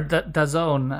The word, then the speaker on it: DAZN: Yeah, so I mean, DAZN are already, or DAZN, as Boaz tells me it's actually pronounced in Italy, have already been a partner of Serie A for a while.